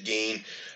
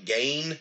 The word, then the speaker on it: Gein: So many people are mispronouncing his name, and it annoys the hell out of me, because as far as I know, everybody knows it's Ed Gein. People call it Gein. People have called it Gain.